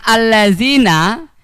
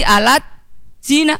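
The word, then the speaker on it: Al-Lazina (الذين): Contoh, ketika ketemu tulisan Al-Lazina, itu kalau ditulis dalam huruf latinnya dan kemudian kita baca sesuai hurufnya, ditulis A, L, A, D, kemudian Z, I, N, A. Terbacanya bukan Al-Lazina, tapi Alat Zina.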